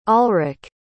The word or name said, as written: alrick